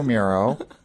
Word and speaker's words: Muro: Okay, well, my name is Michael Muro, and my last name is spelled M-U-R-O, because I get called a lot of different things.